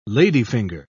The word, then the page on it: ladyfinger: British English